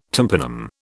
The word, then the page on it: tympanum: British English